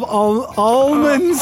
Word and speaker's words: almond: I do love almonds. – Almond. A-U-M-O-N-D. Just almond. – Almond. – Yeah. – Okay. Got almond. – It. Almond. I dropped the L. The L is silent where I'm coming from.